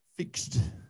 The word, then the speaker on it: FIXD: F-I-X-D. – F-I-X-D. Fixed.